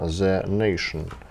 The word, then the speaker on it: The Nation: Итак, местная пресса, а именно The Nation – издание, правильно произношу? – Да, да The, Nation.